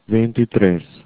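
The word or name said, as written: veintitrés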